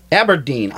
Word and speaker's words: Aberdeen: Mayor Simpson recently read a proclamation which stated Otterdene, or how do you say that? Otterdene. – Is it Aberdeen? – Aberdeen. I'm sorry, God.